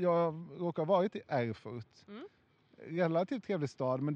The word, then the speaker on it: Erfurt: I staden Erfurt. – Mm, bra uttal. – Tack. Eller det var alltså inte bra uttal? – Nej, Erfurt.